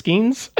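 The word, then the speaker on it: Skenes: Name rhymes with Skeens. – Yeah. Isn't it just Skeens? – I think so. What else rhymes with Paul Skeens, guys? – Leans. I was thinking leans, scenes.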